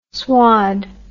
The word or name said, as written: swad